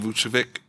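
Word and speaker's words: Vučević: Vucevic.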